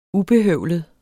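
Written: UBEHØVLET

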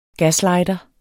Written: gaslighter

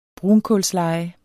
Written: brunkulsleje

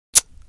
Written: tsk

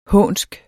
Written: hånsk

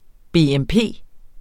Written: BMP

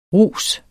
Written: rus

